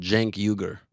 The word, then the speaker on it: Cenk Uygur: But how do you say biggest online news network in the world, Cenk Uygur.